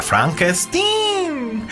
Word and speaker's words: Frankenstein: Y de hecho lo estoy pronunciando como lo pronuncian en la película, Frankenstein.